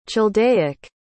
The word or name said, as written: chaldaic